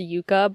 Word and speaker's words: Jukka: For those of you listening, it's pronounced Jukka, but I just want everyone to know it's spelled J-U-K-K-A.